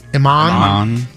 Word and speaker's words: Iman: Iman.